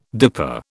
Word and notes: dipper: British English

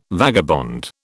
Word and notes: vagabond: British English

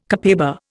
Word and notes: copaiba: Indian

